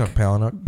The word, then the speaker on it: Palahniuk: I've never actually read one of Chuck Palahniuk. – Palahniuk. I can never pronounce. I always call him Palahniuk.